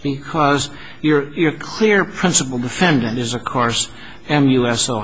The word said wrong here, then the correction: MUSA: MUSA. How do I pronounce that one? – Call it MUSA, Your Honor.